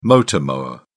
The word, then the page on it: motor mower: British English